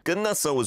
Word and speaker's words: 끝났어: 끝났어 was really good because there's a 상기억 on the first syllable there.